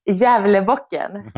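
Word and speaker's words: Gävlebocken: And that's the big, is it Gavlebakken? Is that how you say it? Or Javlebakken? I don't know how you say it. – Javlebakken.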